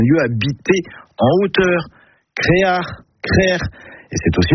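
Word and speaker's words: Krec'h: Et la bonne production est crère ou créar. Créar, c'est une prononciation plus du Nord Finistère, mais on la trouve parfois ailleurs.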